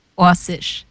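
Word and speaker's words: Oršić: But I believe it's more like Orsic.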